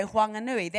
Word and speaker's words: Whanganui: So they say Huakatani, they say Whangarei, they say Whanganui. That's why Whanganui has an H, but you don't pronounce it because that's the dialect of his people.